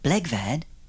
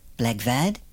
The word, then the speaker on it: Blegvad: Correctly pronounced in English, Blegvad rhymes with Eggbad. Give it a try. Blegvad. Blegvad.